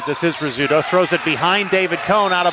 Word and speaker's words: Rzeszutko: You know, you're talking about Rizzuto, Brad, and you were fortunate, we were talking in the pregame, but that's how it's pronounced. His name is spelled R-Z-E-S-Z-U-T-K-O, and they call it Rizzuto.